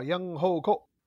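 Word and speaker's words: Younghoe Koo: Y la otra es, a ver, este, si me ayudas con la pronunciación del pateador de los Falcons, John Hoku, ¿cómo es? Uh, Young Hoku. Ya, perfecto.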